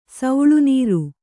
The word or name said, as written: ಸೆಳೆನೀರು